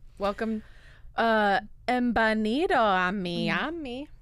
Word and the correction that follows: bienvenido: It's embanido. Bienvenido is how you say it.